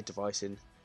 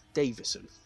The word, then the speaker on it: Deiveson: Well, I say Deiveson, it's Deiveson. So I've been saying that wrong this whole time.